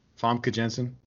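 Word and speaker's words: Famke Janssen: Famke Jensen? How do you say it? – Famke Jensen.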